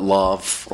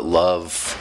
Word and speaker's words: LOVF: I don't know why I decided to release a book that has an unpronounceable title, but I sometimes call it Lovf, or I call it Love. Some people call it Lovf, and some people call it L-O-V-F, and all of those are fine, and that was my fault for not having a more pronounceable title, but you know all the good names are gone.